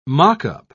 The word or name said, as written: mock-up